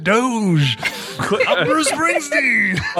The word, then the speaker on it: Doge: Now I know it's pronounced Doge. – I have to say, I'm sorry, Seth. I'm sorry because I wondered the same thing, and I didn't want to admit it, but I wondered the same thing for a while. I mean, how do you pronounce this word? – I, I think everyone who says Doge is just saying it wrong, and they heard somebody say but the people who created Dogecoin are that's what they call it.